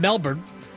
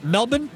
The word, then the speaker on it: Melbourne: Was it Noah Eagle who kept calling it Melbourne? – Oh. – Because that's how they pronounce it right. – in Australia? – Right.